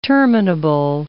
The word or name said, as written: terminable